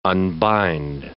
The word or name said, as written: unbind